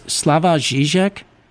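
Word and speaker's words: Slavoj Žižek: So I actually Googled his pronunciation, and I've kind of given him the wrong name many times. His name is Slavoj Zizek.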